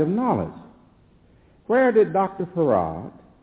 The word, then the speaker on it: Fard: who's called Farad. It's spelled F-A-R-D, but is pronounced F-A-R-A-D, Farad.